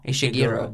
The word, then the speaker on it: Ishiguro: Do you know how to pronounce that name? Kazio? – Which one? – Kazio. – I would guess Ishiguro. – Ishirgo? – Kazuo Ishiguro. – Ishiguro. That's how I would say it.